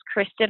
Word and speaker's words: Krystin: My name is Kristin Hernandez. Kristin is spelled K-R-Y-S-T-I-N. Hernandez, H-E-R-N-A-N-D-E-Z.